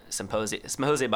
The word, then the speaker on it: Psymposia: If you go to Symposia, by the way, is spelled with a P, silent P, like in psychedelic, you know, because we're cute like that. So it's PSYMPOSIA.